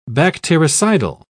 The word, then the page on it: bactericidal: British English